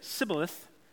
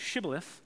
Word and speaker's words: Shibboleth: They knew their enemy wouldn't be able to pronounce the sh in Shibboleth because of their accent and dialogue. So when someone said Sibboleth instead of Shibboleth, their true identity was revealed right away, leading to their capture and punishment.